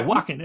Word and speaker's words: Joaquin: Yeah, Joaquin. It's sort of like Walken, but like Joaquin.